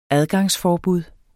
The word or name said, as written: adgangsforbud